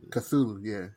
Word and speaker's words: Cthulhu: I was like, I know it's spelled weird, but I've heard it pronounced Cthulhu.